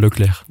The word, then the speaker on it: Leclerc: The first safety car was caused by a crash between Ferrari's Charles Leclerc. Did I say that right? – Charles Leclerc. – There you go.